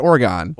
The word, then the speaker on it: Oregon: Is pronounced Oregon.